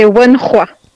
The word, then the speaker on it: Wenhua: Presque, c'est Wenhua. Le H se prononce en chinois. – Merci, je te remercie. W-E-N-H-U-A.